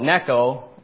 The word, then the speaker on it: Necho: So real quickly here, Pharaoh Necho, at least I think that's how you pronounce it, N-E-C-H-O, Necho.